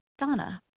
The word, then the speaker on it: Dana: This is Donna B., recovered compulsive overeater, calling from Denver, Colorado. My name is spelled D-A-N-A, even though it's pronounced Donna.